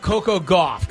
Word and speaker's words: Coco Gauff: How about Coco Gauff? Can we get away with that one? – That one is correct.